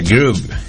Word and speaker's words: Groove: Se escribe Grove con V y doble O y se pronuncia Groove.